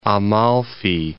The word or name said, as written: Amalfi